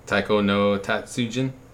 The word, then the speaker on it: Taiko no Tatsujin: Taiko no Tatsujin.